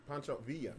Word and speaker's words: Pancho Villa: It's not Pancho Villa. – Pancho Villa. That's it. – That's how you say it.